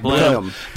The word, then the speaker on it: Blenheim: Hey, you guys are well pronouncing it Blenheim because... – Blenheim? – Say Blenheim. – Yeah. Right, Blenheim. Because that's... – In Gloucestershire. – Because that's lazy talk. That's southern talk.